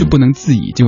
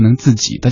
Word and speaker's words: 不能自已: 所以另一个角度证明这样歌曲经典程度，它甚至于改变大家对一些字的一个读音习惯，就像那首《漂洋过海来看你》一样的，明明是不能自已，结果他成不能自己，大家就这么唱下去了。